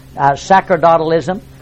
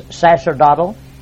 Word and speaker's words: sacerdotalism: Now, you may have heard this pronounced sacerdotalism, and that's not wrong. If you look in your Webster's dictionary, you'll find that it is pronounced both ways, and the C being pronounced as an S, sacerdotal, is the first pronunciation. The other one is a hard C.